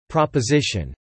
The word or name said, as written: Proposition